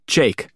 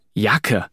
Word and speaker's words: Jake: Das soll ausgesprochen werden wie auf Englisch. Jake. Weil meine Eltern mich nach Jake Gyllenhaal so genannt haben. Das ist einer der besten Schauspieler auf der Welt. Die meisten in der Schule sprechen meinen Namen ganz bewusst falsch aus. Sie sagen Jacke, aber so, dass es sich auf Hacke oder Zacke reimt.